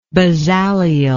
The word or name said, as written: Bezaleelian